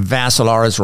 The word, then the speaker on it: Vassilaris: Facebook Live, go to my profile page, Dimitri Vassilaris. Dimitri spelled with three I's, D-I-M-I-T-R-I. Last name Vassilaris rhymes with Assilaris, except you add a V.